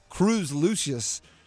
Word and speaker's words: Lucius: You kind of want to call him Cruz Luscious because luscious, but Cruz Lucius, like the what's the word for alliteration, but when it's vowel sounds, like the repeated vowel sound of the ooh just sounds so good.